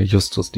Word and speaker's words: Justus: Dann hat AC Abadi oder Abadi, ach, was weiß ich, wie man das ausspricht, hat den Sheriff gespielt und Justice, sagt man das? Justus? Ich kann diesen Namen nicht aussprechen. – Als Fans von Justus Jonas sagen wir Justus D.